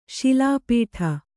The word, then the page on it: ಶಿಲಾಪಟ್ಟ: śilāpaṭṭa